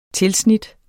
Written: tilsnit

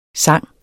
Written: sang

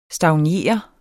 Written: stagnere

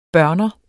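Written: børner

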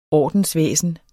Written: ordensvæsen

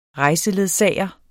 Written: REJSELEDSAGER

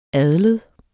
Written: adled

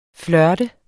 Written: FLIRTEDES

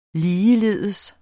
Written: ligeledes

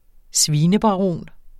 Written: svinebaron

